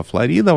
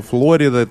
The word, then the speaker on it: Флорида: Мы путешествуем по прекрасному и волшебному штату, который называется Флорида или Флорида. В русском языке правильно Флорида, в английском он называется Флорида, также он назывался и в испанском, как именно с этим ударением, и именно так его назвал первооткрыватель.